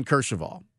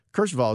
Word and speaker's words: Kercheval: So I started following Ben Kershaval. Ben covers college football. He covers the AAF for CBS Sports. You can follow him at Ben Kershaval. Kershaval is spelled K-E-R-C-H-E-V-A-L.